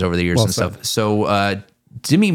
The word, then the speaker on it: Demi: And so, as you know, famously, he was married to Demi Moore for several years. – It's pronounced Demi. – I'm sorry, Demi Moore.